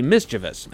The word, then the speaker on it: mischievous: But no matter how vigorous the debate is over the two options, there's really only one way to say it, and it's mischievous.